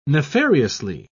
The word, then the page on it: nefariously: British English